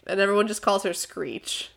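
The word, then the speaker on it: Sckreech: With a K! Actually, with a C and a K. S-C-K-R-E-E-C-H. – Oh, you make my brain hurt. – The K is silent.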